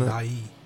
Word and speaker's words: Ndiaye: Yeah, I just don't know how to pronounce his name. It is Ndiaye, isn't Ndiaye. – It? Ndiaye.